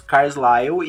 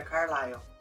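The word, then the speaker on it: Carlisle: Personagens favoritos: Carlyle e a Alice. – Se pronuncia Carlyle.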